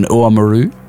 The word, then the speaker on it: Oamaru: Oh, nice pronunciation, Oamaru.